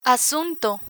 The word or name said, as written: asunto